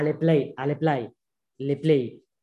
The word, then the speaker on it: Le Play: Podemos citar a Le Play, ¿cómo se dice en francés? En castellano diríamos play, como jugar en inglés.